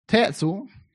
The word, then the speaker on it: Tetzel: The guy named Tetzel, whose name rhymes with pretzel, because his theology was all twisted, was telling folks, you can buy one for you.